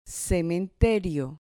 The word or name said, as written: cementerio